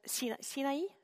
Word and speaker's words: Sinaï: Ils ont quitté l'Égypte, ils sont descendus dans le désert de Sinaï, ça se prononce comme ça en français, en Sinaï.